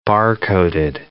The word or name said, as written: bar-coded